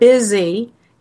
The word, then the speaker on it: busy: Have you ever noticed that the word busy is spelled B-U-S-Y, yet it's pronounced with a Z, busy?